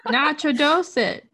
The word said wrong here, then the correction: Nacogdoches: No, nacho doches. Nacho doches.